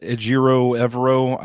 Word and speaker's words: Ejiro Evero: And I know he's interviewing for head jobs, but if you can get Ejiro Evero, I probably just butchered his name. – That's right. You nailed it.